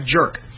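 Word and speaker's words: Tjaurk: It's T-J-A-U-R-K. But if you say his name in English, it comes out like jerk.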